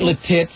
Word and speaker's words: Lititz: Would you pronounce that Lititz? – Lititz? – Lititz. – Lititz. – Lititz. – Lititz. – L-I-T-I-T-Z.